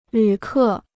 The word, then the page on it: 旅客: lǚ kè